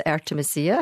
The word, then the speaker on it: Artemisia: She is actually one of my favourite artists, Artemisia, if I can slightly correct your pronunciation.